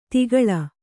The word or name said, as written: ತಗ್ಗಾಳು